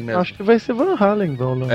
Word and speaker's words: Van Halen: Aqui é nos Estados Unidos é o normal é Van Halen. Van Halen, tá, A então... – pronúncia é americana, né?